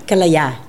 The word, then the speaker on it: Kalaya: Kalaya. – Kalaya. – Yes. Kalaya.